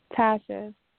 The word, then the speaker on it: Tasha: Tasha. – Spell that. – T-A-S-H-A.